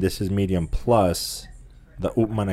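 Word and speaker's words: Upmann: Upman. – Upman. – I always say Upman. I pronounce it like a Spanish, I guess.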